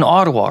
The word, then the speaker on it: Ottawa: So you got founded, it's in Ottawa, right? Because your office is in Canada. I probably pronounced that wrong. – Yeah, no, you nailed it.